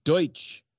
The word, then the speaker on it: Deutsch: It actually, it's a mistaken pronunciation. It originated in German expressionist cinema as a Deutsch angle, but we just now call it Dutch.